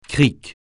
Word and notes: crique: francais

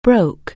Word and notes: break: anglais

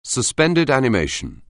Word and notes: suspended animation: anglais

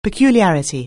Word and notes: peculiarity: anglais

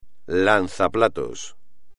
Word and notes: lanzaplatos: espagnol

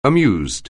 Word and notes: amused: anglais

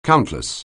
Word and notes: countless: anglais

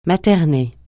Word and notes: materner: francais

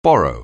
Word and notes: borrow: anglais

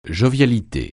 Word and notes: jovialité: francais

